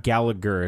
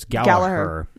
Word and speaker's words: Gallagher: This is a very interesting match because Jack, and it's not Gallagher, it's Gallagher. – Mm-hmm. – I have a friend whose last name is pronounced Gallagher. So that is he American? – Yes, he is. And that's why I pronounce it that way. I apologize. But I did notice they were pronouncing it Gallagher.